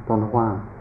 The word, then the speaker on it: Don Juan: In a passage in the book um, by Don Juan, the uh, by sorry Carlos Castaneda, tonight about Don Juan, Don Juan, it's more correctly pronounced.